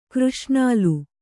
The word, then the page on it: ಕೃಷ್ಣಾಲ: křṣṇāla